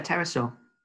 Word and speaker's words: pterosaur: My main worry with that one is, do you say pterosaur? Or do you, like any good housemate in the middle of the night, go with the silent P? – Silent P.